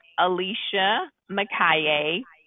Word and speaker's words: Alicia Makaye: Um, you can go to my Instagram page, and it is my name, Alicia Makaye, PhD, um, and that's spelled A L I C I A M A K A Y E PhD.